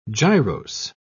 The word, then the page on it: gyrose: British English